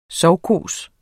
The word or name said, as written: sovkhos